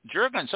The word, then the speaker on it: Juergens: Juergens, like the lotion. – Juergens, okay.